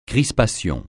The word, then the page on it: crispation: francais